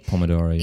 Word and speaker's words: Pomodoro: And one comment I wanted to make on it was like the Pomodoro technique. Hopefully I'm pronouncing it correctly. – Pomodoro, yeah.